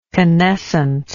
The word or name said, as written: canescence